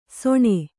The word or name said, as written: ಸೊಣೆ